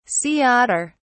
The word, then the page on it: sea otter: British English